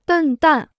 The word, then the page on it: 笨蛋: bèn dàn